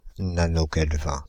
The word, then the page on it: nanokelvin: France (Île-de-France); IPA: /na.nɔ.kɛl.vɛ̃/; IPA: /na.nɔ.kɛl.vin/